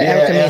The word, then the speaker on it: Erica Mena: That's how you pronounce her name, right? – Yeah, Erica Mena.